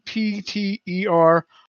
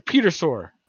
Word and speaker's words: Pterosaur: Pterosaur. It's a sore. Some kind of a sore. A petersaur.